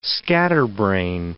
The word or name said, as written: scatterbrain